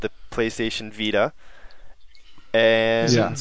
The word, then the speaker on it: Vita: That's the way they've been pronouncing it, at least. – Yeah. – Vita, as in life.